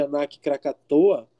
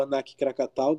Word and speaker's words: Anak Krakatau: Como todos os senhores devem estar sabendo, na madrugada do dia 11 de abril, um vulcão chamado de Anak Krakatoa, ou Anak Krakatau, dependendo da sua pronúncia, que para o idioma local dos indonésios significa filho de Krakatoa.